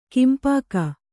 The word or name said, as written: ಕೆಂಪೊಗೆ